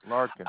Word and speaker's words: Larkan: It's Larkin Henderson. Larkin. – Oh, okay. Larkin, my apology. I misheard you. Spell that first name. – L-A-R-K-A-N.